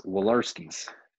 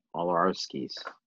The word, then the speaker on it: Walorowski: Walorowski's. – Yeah, I forgot. It's very Polish. You just don't even say the W. It's like Walorowski's.